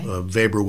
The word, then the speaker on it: Weber: Weber with a W in the German pronunciation.